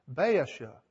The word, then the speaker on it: Baasha: Baasha, is that how you pronounce that? B-A-A-S-H-A.